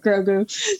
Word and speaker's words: Grogu: I've just been following along, and I absolutely love Grogu so much.